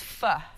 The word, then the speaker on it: pho: It's pho.